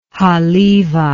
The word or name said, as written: halevi